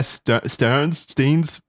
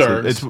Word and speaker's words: Stearns: Anyway, so the father recommended Epstein to Alan Greenberg, an executive at Bear Stearns. I don't know how to pronounce Stearns. – It. – Stearns. – It's one of those Stearns.